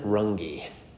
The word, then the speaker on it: Runge: Stephen Runge is how you say his name.